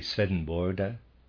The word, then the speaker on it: Swedenborg: Readers aside, the pronunciation in Swedish of Swedenborg is roughly Svedenborda, and it's a little rough for me, I'm afraid, and I've decided I'm going to go with the English on that.